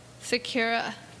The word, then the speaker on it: Secura: Secura. – They said Sakura.